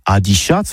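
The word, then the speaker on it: adiciatz: Eh oui, adicias, que c'est prononcé au tabé adichats, voire adéchats, surtout en Gascogne toulousaine.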